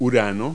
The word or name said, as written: uranus